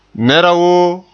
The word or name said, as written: woo